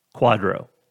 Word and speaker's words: QDRO: The initials are QDRO, and it's pronounced Quadro.